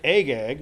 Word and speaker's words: Agag: We've talked about this before when we talked about egg, um, um, Agag, Agag, Agag, there I can say it. If I start with a long A, I'll work it out.